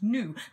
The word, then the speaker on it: gnu: It's pronounced GNU. It's actually pronounced NU. Thus rendering the song inaccurate. It's actually a NU.